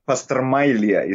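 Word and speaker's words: pastrmajlija: You can find a good pastramilje, but pastramilje is the right pronunciation.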